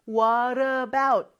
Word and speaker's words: what about: What about 이잖아요. 그런데 이게 연음이 되면서 마치 하나의 단어처럼 What about 이렇게 변하거든요. 물 있잖아요, 물. Water라고 많이 발음 안 하죠. 이 T가 모음 소리 사이에 껴가지고 Water 이렇게 발음이 되는 것처럼 여기서도 what의 마지막 t가 그 다음에 오는 소리도 모음 소리라서 what about 이렇게 발음이 돼요.